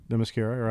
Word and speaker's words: Themyscira: they're on Themyscira. Is that how you pronounce it? – Themyscira, right.